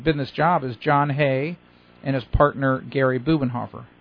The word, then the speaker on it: Hoeh: John Hay, and the way you spell that last name, it's kind of unusual. It's H-O-E-H. – Oh. – Yeah, H-O-E-H. – Okay. – John Hay. Yeah, I know. It doesn't even remotely sound like it looks.